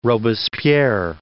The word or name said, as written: Robespierre